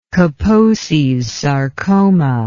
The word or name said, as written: Kaposi's sarcoma